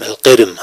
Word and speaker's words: القرم: ما هي القرم؟ القرم هكذا تنطق.